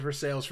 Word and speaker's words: Versailles: I was like, yes, Versailles. That's where it is, because you're from Kentucky and you presumably know that that's how it is pronounced there. So, yes, I will call it for sales from now on because I don't feel like getting beat by a hick.